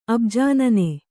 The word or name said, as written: ಅಬುಜಾನನೆ